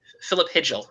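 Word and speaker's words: Filip Chytil: His name is exactly, it's Hithil? – Philip Hidgel. It's pronounced Hidgel.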